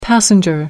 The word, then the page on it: passenger: American English